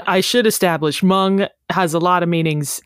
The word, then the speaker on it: mung: Your word today is Mung, M-U-N-G, also sometimes pronounced Munge, M-U-N-G. – Oh, Munge. – Yeah, I would say more historically pronounced Mung.